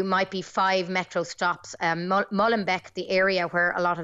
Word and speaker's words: Molenbeek: Molenbeek is the name of the area where a lot of the suspects of the Paris attacks have been living.